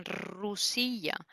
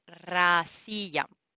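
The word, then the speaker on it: rasilla (Россия): Y ella me dijo, no, es rasilla. Perdón mi pronunciación si alguien sabe hablar ruso. ¿eh? Em, y, ella me dijo, dice, eso de rusilla lo dices por el español, pero en realidad es rasilla.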